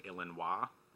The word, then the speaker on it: Illinois: And then this weekend we'll be headed up to Tinley Park, Illinois. Did you know that's how you pronounce Illinois? It's French.